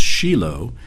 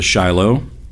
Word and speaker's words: Shiloh: But Shiloh originally meant, it's a Hebrew word that actually means, it's pronounced Shiloh. In fact, that's how the Jews today pronounce it. If you go to Shiloh in Israel, they refer to it as Shiloh.